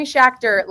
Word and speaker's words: Schachter: I am here with Jeremy Schachter, and we prepared this because I had to figure out how to pronounce this. It's Jeremy Schachter like tractor.